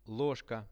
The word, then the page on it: lozhka: Russian